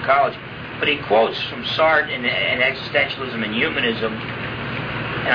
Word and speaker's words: Sartre: If you take out that page that says Sartre on the top,